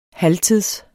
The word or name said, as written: halvtids